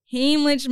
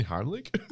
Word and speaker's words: Heimlich: The worst part is I choked in capital letters on my vomit, and my ex had to give me Heimlich maneuver on the shower floor, both of us naked. Did I say that right? – Do you mean Heimlich?